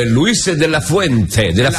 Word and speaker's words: Luis de la Fuente: Devo fare i complimenti al mister della nazionale spagnola, però Luis de la Fuente, Ho detto bene perché mi sono levato i provvisori per dirlo, pensa.